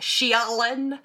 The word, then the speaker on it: Shaylynn: It's spelled Shaylynn, like Shialyn.